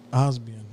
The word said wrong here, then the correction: Obsidian: I said it wrong. Obsidian.